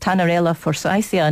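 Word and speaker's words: Tannerella forsythia: People with double the amount of a bacteria called Tanarella forsythia. Now, I'm not sure if that's the correct pronunciation. You might correct me, Simon. – That sounds okay.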